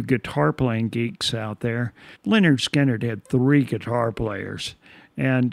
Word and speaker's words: Lynyrd Skynyrd: Before Charlie Daniels, we had Lynyrd Skynyrd, one of their classic songs, "Simple Man," from their debut album, pronounced Lynyrd Skynyrd, which was produced by Al Kooper and released in the summer of 1973.